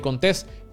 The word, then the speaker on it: Contés: Mi nombre es Miguel Contés con acento en la E y nos vemos en la próxima.